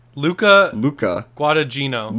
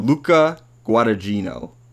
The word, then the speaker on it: Guadagnino: Luca Guadagnino. – Luca Guadagnino.